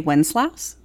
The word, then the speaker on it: Wenceslas: If it's easier, you can say Wenceslas, because that is also an accepted pronunciation of it, though okay. not usually what one sings.